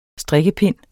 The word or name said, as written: strikkepind